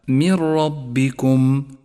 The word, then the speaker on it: من ربكم: والواجب هنا أن نسقط النون الساكنة من اللفظ تماما وأن نشدد حرف الراء الذي بعدها، فيصير اللفظ هكذا: من ربكم، ولذلك لونت النون باللون الرمادي إشارة إلى عدم نطقها.